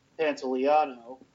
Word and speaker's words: Pantoliano: And then you had Dick the Cat, which was Joe Pantoliano.